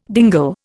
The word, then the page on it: dingle: Indian